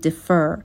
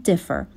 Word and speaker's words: differ: differ یک فعل هست به معنای تفاوت داشتن، استرس روی بخش اول کلمه هستش، نمیگم differ، به این صورت تلفظ نمی کنم، میگم differ.